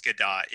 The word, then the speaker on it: Gadot: I found out I was saying her name wrong. – Is this Gadot? It's not Gadot? – It's Gadot, yeah.